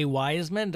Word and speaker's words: Weismann: Weismann.